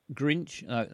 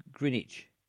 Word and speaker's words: Greenwich: Grinch. Is it Grinch? – No. – Greenwich. – Yeah. – Greenwich, where'd you come from? I come from Greenwich.